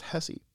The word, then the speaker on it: Hesse: Shout out to our German listener in, I believe it's pronounced Hesse. Hesse, Germany? H-E-S-S-E.